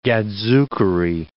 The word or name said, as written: gadzookery